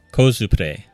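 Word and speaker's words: コスプレ: How would you pronounce it in Japanese? – Kozupre. – Ah. – It's just it's from two words, costume and play.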